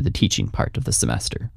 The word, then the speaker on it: semester: And how do you actually say semester in Slovak? – That's another easy one, actually. Semester. – Oh. – The pronunciation is slightly different, but the word itself is basically the same.